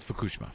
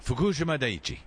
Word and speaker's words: Fukushima: I do, but before that, I think it's Fukushima. – What did she say? – She said she wasn't sure how to pronounce it. – Oh, well, we think it's Fukushima. Fukushima Daiichi. – Yeah, Fukushima.